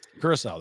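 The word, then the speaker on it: Curaçao: Curacao, that's how we pronounce that.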